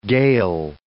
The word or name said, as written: gale